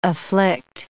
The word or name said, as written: afflict